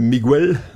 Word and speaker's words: Miguel: We hebben hier Miguel, op zijn Spaans uitgesproken.